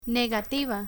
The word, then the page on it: negativa: Spanish